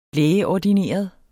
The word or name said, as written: lægeordineret